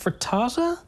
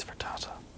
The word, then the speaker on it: frittata: I think it's pronounced frittata. – You say frittata. I say frittata. – It's frittata.